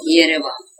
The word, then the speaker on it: Yerevan: That's Yerevan? – Yerevan. That's the capital city of Armenia. – How do you say it? – Yerevan.